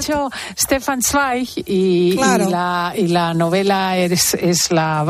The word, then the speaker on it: Stefan Zweig: Stefan Zweig.